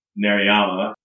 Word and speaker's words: Narayama: You had seen not that long ago and wrote a review for one more film blog of The Ballad of Narayama.